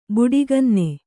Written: ಬುಡಿಗನ್ನೆ

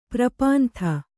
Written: ಪರಿಪಂಥಿ

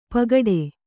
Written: ಪೋಗೊಡು

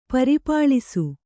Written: ಪರಿಪಾಳಿಸು

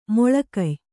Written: ಮೊಳಕಯ್

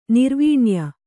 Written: ನೀರ್ವನೆಯ

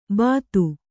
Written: ಬದು

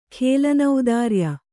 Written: ಖೇಲನೌದಾರ್ಯ

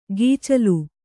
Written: ಗೀಚಲು

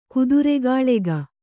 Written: ಕುದುರೆಗಾಳೆಗ

